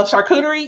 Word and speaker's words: charcuterie: So I love seeing your videos on flowers and then when you put together, and I'm going to butcher the, is it chaturi? – Oh, charcuterie. Yeah. Charcuterie.